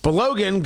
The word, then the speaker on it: Balogun: B-A-L-O-G-U-N. I believe it's pronounced Balogun, and that's the way I'm going to pronounce it.